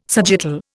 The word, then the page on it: sagittal: Indian